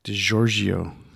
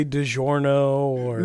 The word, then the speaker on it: DiGiorgio: Greg Christian left, and then they brought in Steve DiGiorgio. Haha, I said it right. – And you didn't say DiGiorno. – No, I didn't say DiGiorno. I didn't say Di Gregorio. I said it correctly.